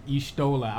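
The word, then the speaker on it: Y'shtola: First time I heard Ishtola, I was like, oh. – That's how you pronounce it.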